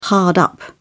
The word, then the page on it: hard up: British English